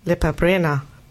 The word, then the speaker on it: Lepa Brena: Nämlich Lepa Brena. Habe ich richtig ausgesprochen eigentlich? – Ja.